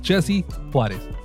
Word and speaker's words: Jesse Juarez: You know, just Jesse Juarez. Two syllables en cada uno.